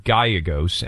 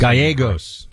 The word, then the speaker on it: Gallegos: but Ruben Gallegos, and I might be pronouncing his last Gallegos.